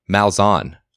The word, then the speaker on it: Malzahn: And by the way, it is pronounced Malzahn. I looked it up. – Oh. – So we've had that conversation with Ty Hildenbrandt a few weeks ago. He was right. That's how it's pronounced.